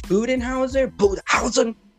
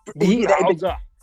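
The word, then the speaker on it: Budenholzer: Coach Budenhauser, he is. – Budenhauser. – Yeah, well, thank you, thank you. I knew there was a. I'm sorry for the mispronunciation, dude.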